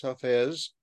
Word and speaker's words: Hafez: So we have all these where Hafez is, and I was mispronouncing that earlier, where Hafez.